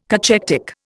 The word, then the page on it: cachectic: Indian